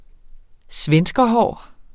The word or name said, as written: svenskerhår